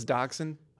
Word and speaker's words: dachshund: Is dachshund? – I've been led to believe so.